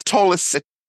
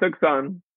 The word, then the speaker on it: Tucson: such as it's not Tolson, it's Tolson. It's not. Oh God, there's a whole bunch. Tucson. It's Tucson.